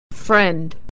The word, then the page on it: friend: /e/